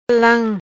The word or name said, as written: Lam